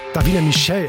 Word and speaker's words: Davina Michelle: Chris Martin und Coldplay, We Pray, für euch auf SR1 und das ist Davina Michael. Davina Michelle, Entschuldigung, so spricht man sie aus.